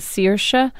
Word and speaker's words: Saoirse: But actually, the Irish way to pronounce it is Saoirse.